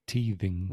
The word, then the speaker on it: teething: So although it's written yeah. like teeth, dientes, it's not pronounced like teeth. It's teething, teething.